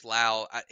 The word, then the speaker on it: Lowe: Brandon Lowe is really good. That is Lowe. Nathaniel Lowe used to play for the Rays, and I used to get it mixed up all the time because they pronounce their names different, even though they had the same name. But I do believe his is Lowe.